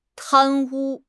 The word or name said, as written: 贪污